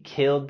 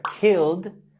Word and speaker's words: killed: La terminación ED en la palabra killed lo pronunciamos con el sonido D.